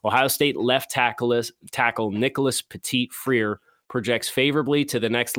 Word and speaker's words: Petit-Frere: So Nicholas Petit-Frere, and I have the phonetic pronunciation guide here next to me because it's always been Petit-Frere. It's Petit-Frere.